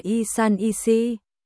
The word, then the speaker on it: 1314: Trong tiếng Trung, nó được phát âm là y san y si,